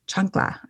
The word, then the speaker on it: chancla: Chancla. I said that right. – Yes. – Okay. – You got it.